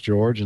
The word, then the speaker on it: Jorge: His name is George, like mine, except it's spelled J-O-R-G-E, but it's pronounced George in English. And his last name, and I'm not sure I can pronounce this correctly, it's C-O-M-A-S, and so maybe that's Comas.